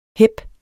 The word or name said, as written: hep